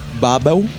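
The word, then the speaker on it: Babbel: Chama Babel, que é alemão falando Babel, tipo uhum. Torre de Babel. Uhum. B-A-B-B-E-L. É, são dois Bs. Dois Bs no, no, na segunda sílaba.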